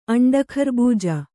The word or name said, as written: ಅಂಡಖರ್ಬೂಜ